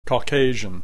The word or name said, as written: caucasian